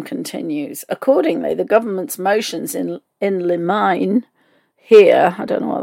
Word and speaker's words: in limine: So I just looked that word up, and it's pronounced in limine,